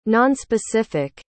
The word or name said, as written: nonspecific